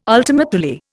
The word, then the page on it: ultima Thule: Indian